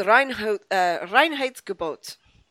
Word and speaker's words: Reinheitsgebot: It's Reinheitsgebot.